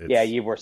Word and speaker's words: Ybor: Ybor City.